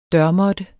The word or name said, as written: dørmåtte